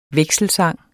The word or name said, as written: vekselsang